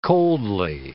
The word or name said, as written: coldly